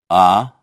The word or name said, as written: а